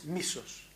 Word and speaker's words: Μίσος: Ja, aber die Betonung ist Μίσος.